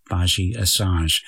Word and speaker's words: Badi Assad: Her name is spelt B-A-D-I-A-S-S-A-D, Baji Asaj.